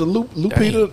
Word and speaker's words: Lupita: Oh, so the L, yeah. – Is silent. – No, it's a strong ass L. It's a Lupita Dang.